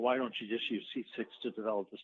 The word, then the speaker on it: CCIX: They pronounce it C6, and also OpenCAPI.